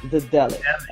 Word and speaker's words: Daedalic: So could you just tell me, so I could learn and everyone can see this, how exactly do I pronounce the name so that I could learn it? And I know a lot of people that are watching this don't know how to pronounce it either. So, you know, tell me, how do I pronounce the name correctly? – It's Daedalic Entertainment. – Daedalic. Okay. See, because yeah. I would probably go with the A too much, and I think that's where I would Daedalic mess up.